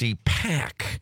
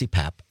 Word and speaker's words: CPAP: Okay, first of all, I tried to correct you guys saying that you guys were saying it wrong, that it's CPAC. And then you, no. – Yeah. – CPAP. – See, I thought you were getting it wrong the whole time in your career. – Airway pressure. – They own the company, and right. They've been saying it wrong. – Uh-huh. – It's CPAP, and that stands for something.